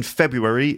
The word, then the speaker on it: February: F-E-B-R-U-A-R-Y. But it's actually pronounced February. I think there's several ways to say this month, okay? February, or February. So February, four syllables, or February, three syllables.